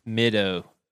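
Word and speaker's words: Middaugh: The last person, it's one word, and it's spelled M-I-D-D-A-U-G-H. I would just say middo.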